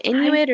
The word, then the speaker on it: Inuit: And also, is it Inuit? Is that how you say, like, the people of Alaska? Is it Inuit, or is it? – That's correct.